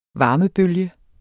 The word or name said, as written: varmebølge